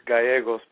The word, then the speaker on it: Gallegos: Yeah, you just, you don't have to pronounce the L's, it's Gallegos, but that's good enough.